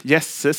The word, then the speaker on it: Jesse: David, kung David, ni känner till, tror jag, när Samuel, profeten, kommer till, hur uttalar vi Davids pappas namn? Jesse, säger vi, va? Är det okej?